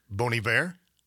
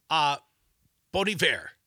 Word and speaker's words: Bon Iver: How do you say the band? – Bon Iver. – Bon Iver. – Bon Iver. – Like a French pronunciation, like the Olympics this year. A lot of French in the Olympics.